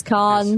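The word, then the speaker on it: Cannes: Cons. – Cons. But like, the thing I have to say it like I'm pretentious. Cons. Yes.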